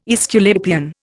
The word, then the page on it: Aesculapian: Indian